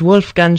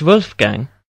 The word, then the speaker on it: Wolfgang: My name is Wolfgang Schmidt. That's Wolfgang. – Wolfgang? Okay, how do you spell that? – Wolfgang. – Okay, G-A-N-G, uh-huh.